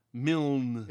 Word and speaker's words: Milne: Milne.